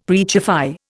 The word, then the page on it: preachify: Indian